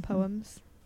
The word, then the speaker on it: Poe: It was like one of the shortest Edgar Allan Poe poems that I know. And Poe rhymes with no, because yeah.